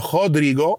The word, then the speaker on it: Rodrigo: a 4-4-2 diamond. Now, while that has affected the likes of, let's say, Rodrigo, or Rodrigo, if you want to use the Portuguese pronunciation,